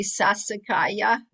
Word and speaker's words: Sassicaia: So he was telling us, and I know when I was in Tuscany, they had a wine called, am I pronouncing it correctly, Sassicaia? – Of course, that's the most Yes.